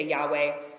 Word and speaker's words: Yahweh: The other place in the Bible where you will see all capital letters is in the Old Testament when the proper name of God, which in Hebrew is YHWH, sometimes we say Yahweh, Jews don't pronounce that word at all.